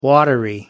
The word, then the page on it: watery: American English